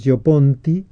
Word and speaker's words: Gio Ponti: Sí, está, que se escribe, eso directamente nos lleva a www, es Yo Ponti.